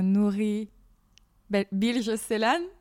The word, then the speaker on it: Nuri Bilge Ceylan: c'est les herbes sèches de Nourri Bilge Célan.